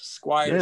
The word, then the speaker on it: Squyres: It's last name is S-Q-U-Y-R-E-S, Squires.